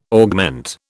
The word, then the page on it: augment: British English